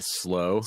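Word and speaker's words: Slö: Walrus Audio Slu. How do you say that? – Oh, the slow. – Slu. – Slow.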